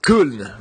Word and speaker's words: Köln: Yeah, I've I've been to uh, to Köln, right? Isn't that how you say it in German? Yeah, right.